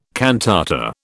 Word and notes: cantata: British English

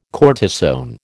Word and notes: cortisone: American English

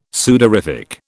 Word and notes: sudorific: British English

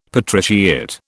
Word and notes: patriciate: British English

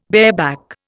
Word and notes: bareback: Indian

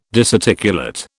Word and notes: disarticulate: British English

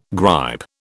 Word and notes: gripe: British English

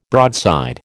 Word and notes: broadside: American English

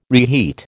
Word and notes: reheat: American English

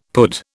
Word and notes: pud: British English

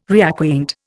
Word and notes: reacquaint: Indian

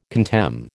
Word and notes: contemn: American English

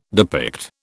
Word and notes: depict: British English

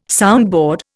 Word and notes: soundboard: Indian

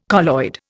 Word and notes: colloid: Indian

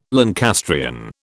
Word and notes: Lancastrian: British English

